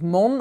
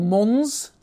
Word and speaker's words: Mons: So the town of Mon or Mons, depending how you like to pronounce it.